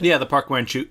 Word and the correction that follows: Park Chan-wook: Park Chan-wook.